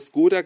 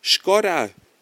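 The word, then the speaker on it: Škoda: Vielleicht habt ihr es ja gehört, Skoda wird in Deutschland immer Skoda gesprochen, obwohl die Marke eigentlich Skoda äh, ausgesprochen wird.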